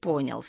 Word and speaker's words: понял: Оборвав смех, он грозно произнес: «Что вы попали во вредный цех?» «Намек понял», — сказал он, сделав ударение в слове «понял». «На последний слог. Понял.